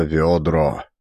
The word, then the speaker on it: ведро: Петухи горланили, предвещая ведро. Примечание от чтеца. Ведро. Старинное слово с ударением на первый слог.